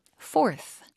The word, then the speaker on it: forth: F-O-R-T-H 발음이요. – Fourth. – Fourth. 네. – Fourth. 네 번째 그 fourth하고 똑같아요. – 발음은 똑같죠.